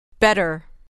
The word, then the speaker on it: better: این میشه بهتر گوش و تکرار Better. Better. Der. Better. Better. در اینجا و در خیلی موارد ت مخصوصا در تلفظ آمریکایی به صورت د نازک تلفظ میشه بگید